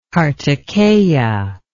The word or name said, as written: karttikeya